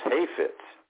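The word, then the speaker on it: hafetz (חפץ): The Hebrew word for purpose is the word kaifetz. It's pronounced hafetz.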